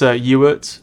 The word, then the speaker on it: Huart: No, it's Ewart. Sounds like Stuart, but take off the ST at the beginning.